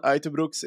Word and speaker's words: Uijtdebroeks: Kian Uytterbroeks is his name. That's how you pronounce it.